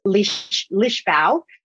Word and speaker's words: Lisboa: So for us, it was in Lisbon, Portugal, which they pronounce Lisbão. I kept seeing, you know, for us, it's spelled Lisbon. And I kept seeing Lisboa. And I thought, what's going on here? But that's how they say it.